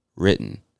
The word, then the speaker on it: written: W-R-I-T-T-E-N. Written. Another one of those words with the funky pronunciation, because you could say written, and that's fine, but I would say written.